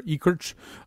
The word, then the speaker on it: Ekirch: You got it entirely correct. Ekirch.